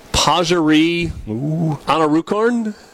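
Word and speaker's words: Pajaree Anannarukarn: Pajari Anarukorn? – Yeah, that's exactly how you pronounce that, actually. – Or is it Pajari? – No, you had it right.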